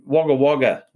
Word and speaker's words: Wagga Wagga: And in Wagga Wagga, which is the pronunciation of the town.